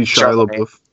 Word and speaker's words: Chalamet: And the kid, Timothy Chalamet, I don't know how to pronounce his name, but... – Oh, it's Timothy Chalamet.